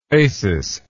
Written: Acis

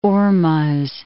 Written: Hormoz

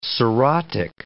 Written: cirrhotic

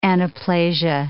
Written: anaplasia

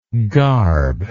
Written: garbless

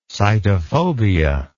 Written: sitophobia

